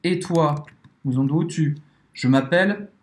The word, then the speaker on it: m'appelle: Et « m'appelle », m'appelle. é aberto isso. M'appelle e, e acaba fini. Eu já tava falando em francês. E la prononciation fini ou elle », A pronúncia vai acabar no L.